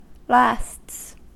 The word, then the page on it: lasts: American English